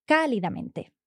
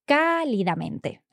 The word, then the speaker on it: cálidamente: So I took the adjective cálido from the feminine and then added the suffix, cálidamente. Excelente. ¿Y con la tilde? Sí, sí, sí. I didn't forget the accent. So it has an accent on the first A, cálidamente. Excellent.